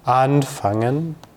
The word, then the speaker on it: anfangen: So it's about the stress. So with the, or for these separable verbs, you have the stress on the first syllable. So einladen, anfangen, and abholen, I'm exaggerating here to make it clear.